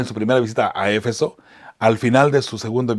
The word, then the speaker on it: Éfeso: Bueno, creo que la ciudad se llama Éfeso, pero algunas personas lo llaman nomás Éfeso nada más, o Efeso, sin el acento en la E, ¿no?